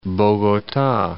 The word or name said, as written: Bogota